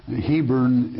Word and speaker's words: Hebron: Hebron is the correct pronunciation. We say Hebron today, alliance.